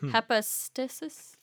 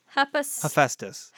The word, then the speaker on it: Hephaestus: Followers of the god, you probably know how this is pronounced, Hepistesis? – Hepatitis. Hephaestus.